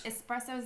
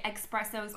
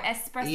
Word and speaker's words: espressos: They're espressos. Is that how you say it? What's the correct way? Some people say expressos, but they're espressos.